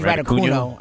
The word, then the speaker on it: Raducanu: And this Emma Raducanu. – Yes. – Is that how you say it? – I think Raducanu. I don't think she's Latin.